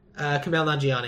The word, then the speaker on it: Kumail Nanjiani: Kumail Nanjiani. – Yes. Oh, yeah. He's been everywhere lately. – I like him as a comedic actor. – He is. Kumail Nanjiani, I think, is actually older than you think he is.